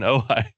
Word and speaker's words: Ojai: Gavin Ortland is the senior pastor of First Baptist Church of Ojai in Ojai, California. Am I saying that right, Gavin? – Yeah, you got it right. – All right, in Ojai.